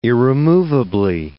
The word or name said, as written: irremovably